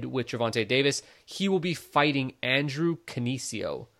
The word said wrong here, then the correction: Cancio: Cancio. – Hey, man. – That's how they pronounce it on the broadcast anyway.